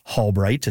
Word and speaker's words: Halbright: That's so interesting, and I can confirm it's Albright. It's spelled Halbright.